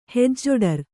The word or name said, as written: ಹೆಜ್ಜೊಡರ್